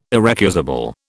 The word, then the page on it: irrecusable: British English